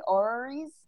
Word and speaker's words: orreries: Am I even saying it correctly? Orreries? – Orreries. That's a real word.